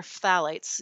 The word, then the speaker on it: phthalates: Technically, there are supposed to be they're phthalates. You could kind of you roll right over the th and go straight to the th, but you are supposed to pronounce both of those sounds.